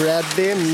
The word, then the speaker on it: Bradley: Det är Bradley, Matt Bradley, med touch då av det nordamerikanska uttalet.